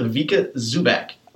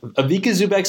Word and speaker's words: Ivica Zubac: I, I believe we're going with Ivica Zubac. Ivica Zubac sounds perfect to me.